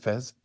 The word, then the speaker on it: Fez: Am I saying that right, Fez? – Yeah, you've got it right.